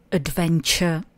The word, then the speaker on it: adventure: A jestli to znáte taky, snadno si zapamatujete, že slovíčko adventure znamená dobrodružství. Rozdělte si zase výslovnost na tři slabiky. Adventure. Adventure.